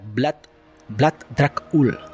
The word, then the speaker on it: Vlad Dracul: Así que Vlad II, por pertenecer a la Orden del Dragón, lo empezaron a llamar Vlad el Dragón, que en valaco se pronuncia Vlad Dracul.